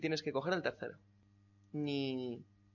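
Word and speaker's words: Ni (你): Ni. – Un cuarto acento estupendamente hecho.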